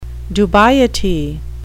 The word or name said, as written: dubiety